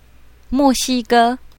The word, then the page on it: mo4xi1ge1: mandarin